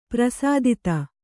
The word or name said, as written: ಪರಿಸ್ಥಿತಿ